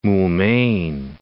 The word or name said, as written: Moulmein